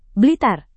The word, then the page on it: blitar: Indonesian